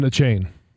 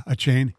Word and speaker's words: Achane: It was Arcane from Texas A&M. Is that the way you pronounce his name? – Yeah, Devin Achane. – Yeah, Achane.